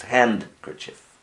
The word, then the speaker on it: handkerchief: So I spelled it H-A-N-K-E-R-C-H-I-E-F. It's H-A-N-D, but you don't pronounce it. It's handkerchief. Not handkerchief, but I left out the D.